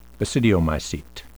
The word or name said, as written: basidiomycete